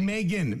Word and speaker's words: Meghan: Oh yeah, it's, it's Megan, like eggs.